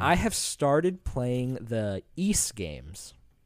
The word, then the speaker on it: Ys: pronounced East, like geese without the G.